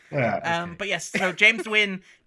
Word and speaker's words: Nguyen: And I was surprised they were pronouncing it like that, but they were saying Nguyen.